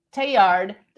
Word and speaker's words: Teilhard de Chardin: Teilhard de Chardin, a Jesuit priest who was quite the thinker, and a lot of people quote him.